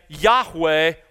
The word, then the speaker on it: Yahweh: When we pronounce the name of God, we say Yahweh, which means he is.